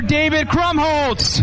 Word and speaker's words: David Krumholtz: Ladies and gentlemen, let's give it up for David Krumholtz. Did I say that right? – Yeah, that's right. You got it good.